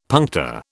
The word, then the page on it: puncta: British English